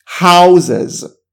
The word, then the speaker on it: houses: لغت house وقتی بره با حالت جمع به این صورت تلفظ میشه. Houses. خیلی باحاله. House حالت جمعش میشه houses. این نکته ریز تلفظی، حواست به این نکته باشه.